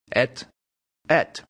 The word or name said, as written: et et